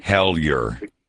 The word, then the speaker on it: Hellyer: Hellyer.